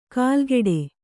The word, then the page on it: ಕಾಲ್ಗೆಡೆ: kālgeḍe